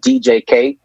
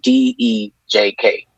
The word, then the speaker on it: DEJK: It's it's pronounced out DJK, but it's spelled out D E J K, and the, the single's called Vaccine. Go check it out. So it's not Dej K. No. – Okay. – DJK.